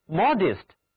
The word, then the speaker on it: modest: 如果我们给表示尺度的词根 mod 加上一个形容词后缀 est，构成的单词人人都非常向往，这就是 modest， modest。请注意字母 e 在这里读作短 e， modest，保持在一定的尺度之内，有节制的。